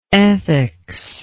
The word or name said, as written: ethics